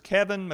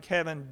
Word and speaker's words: Keven: There's Keven McQueen, which is the author site, and Keven D.